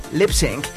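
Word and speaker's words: Lipps Inc.: El nombre del grupo, Lip Sync, se pronuncia exactamente igual que Lip Sync, así como hacer el playback con la boca, ¿ok?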